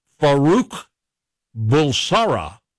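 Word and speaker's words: Farrokh Bulsara: Freddie Mercury, Farouk Bulsara. – I knew that. – Did you know that? Am I pronouncing that right? – Yeah, you did a great job.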